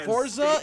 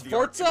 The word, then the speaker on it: Forza: That's how you say it, not Forza. It's Forza. Conversion.